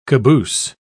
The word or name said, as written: caboose